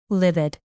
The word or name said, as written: livid